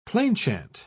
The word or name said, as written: plainchant